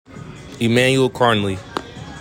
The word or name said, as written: EMMANUEL KARNLEY